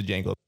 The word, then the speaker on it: Django: yeah, I think the Django. – The D is silent.